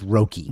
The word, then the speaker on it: Roki: This superstar, how do you say his name? Is it Roki Sasaki? – I believe it's Roki. – Roki, okay.